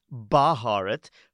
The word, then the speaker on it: Bharat: Bharat. No, not Bharat. Not Bharat. Bharat. See, the B and H are right next to each other. Bharat. Come on, you can say it. Bharat.